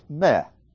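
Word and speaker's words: meh (με): For example, the word for me in Greek is spelled M-E. Pronounced meh. That's the normal me.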